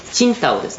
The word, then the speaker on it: 青島: 特にチンタオですね、青島と書いてチンタオですね、